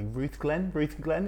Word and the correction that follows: Rutherglen: is that where you're from? – So where I was actually born in the hospital was Rutherglen.